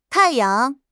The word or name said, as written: tai4 yang5